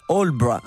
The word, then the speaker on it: Aldeburgh: Côté anglais, Benjamin Britten, lui, se retire dans les années 40 sur la côte est du Royaume-Uni, à Aldeburgh, un petit village qui s'écrit... – Aldeburgh, absolument pas comme il se prononce, donc.